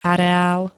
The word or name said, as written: areál